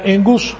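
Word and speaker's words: engus (ἐγγύς): Esta palabra engus, cuando hay dos G seguidas, la primera se pronuncia como una N.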